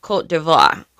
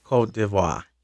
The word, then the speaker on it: Côte d'Ivoire: Ivory Coast, but I believe it is pronounced Côte d'Ivoire. – Côte d'Ivoire.